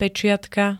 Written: peciatka

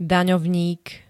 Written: daňovník

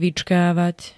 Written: vyckavat

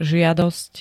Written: žiadosť

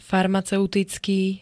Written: farmaceuticky

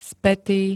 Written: spätý

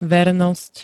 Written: vernosť

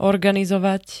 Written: organizovať

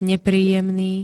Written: nepríjemný

